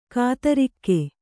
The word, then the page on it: ಖದಿರಕ: khadiraka; Kannada